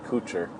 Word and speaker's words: Couture: I always say Kator or yeah, Kotor, Kator. I guess it ends up being Couture. Um, just because I've always thought that was a nicer way to pronounce it. um, I don't know, it just sounds better.